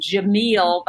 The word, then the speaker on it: Gemmel: Jameel, that's how it's pronounced, G-E-M-M-E-L, and Tim.